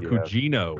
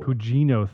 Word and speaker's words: Gugino: Carla Guino, Gugino, I can't pronounce her last name. – From Gerald's Game? – Yes. – Carla Cugino. – Cugino, thank you.